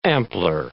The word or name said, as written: ampler